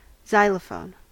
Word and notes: xylophone: American English

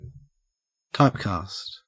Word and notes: typecast: Australian English

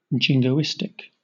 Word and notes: jingoistic: British English

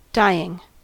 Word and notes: dying: American English

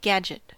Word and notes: gadget: American English

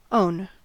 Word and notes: own: American English

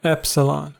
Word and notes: epsilon: American English

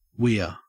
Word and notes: weir: Australian English

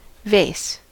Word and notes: vase: American English

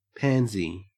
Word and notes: pansy: Australian English

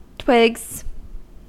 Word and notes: twigs: American English

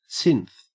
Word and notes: synth: Australian English

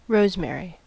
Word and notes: rosemary: American English

